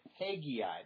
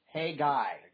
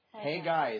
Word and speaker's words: Haggai: We always say, most people say it Haggai, but it's actually just Haggai. Haggai is technically the way you say the name.